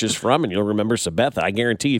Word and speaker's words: Sabetha: Sabetha.